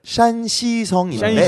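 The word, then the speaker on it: 샨시성인데 (山西): 이거는 이제 중국어는 샨시성인데. – 샨시.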